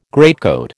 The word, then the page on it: greatcoat: American English